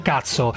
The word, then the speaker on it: KUTSO: Allora, si scrive KUTSO, che non è TSO, anche se ogni tanto sarebbero da TSO per quello che fanno, cioè trattamento sanitario obbligatorio, ma si chiama, io ho capito come si pronuncia, cuzzo o cazzo? – Cazzo, si pronuncia cazzo, dunque cioè, sono ideali per questa trasmissione, Parenzo, capito?